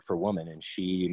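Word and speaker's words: sídhe: So the word, because the gods retreated, the Tuatha Dé Danann retreated under the hills, under the she, and became equated with the hills, and therefore the she. – Yep. – Which, of course, is spelled S-I-D-H-E in modern Irish.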